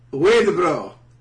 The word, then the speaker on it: Huidobro: Daniel, who I know is Colbolo, James, Malachi, Pedro Garcia Huidobro? – Come on, dude. – Huidobro? – You don't pronounce the H, he's Chilean. – Huidobro.